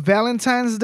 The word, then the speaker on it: Valentine's: She's like, you said Valentine's with the M. That's not how you say it.